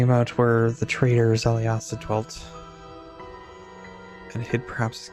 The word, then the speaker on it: Xaliasa: Foundation stones from each would know where the traitor Zaliasa dwelt, and where perhaps he hid his key to Runeforge. And Zaliasa is spelled X-A-L-I-A-S-A.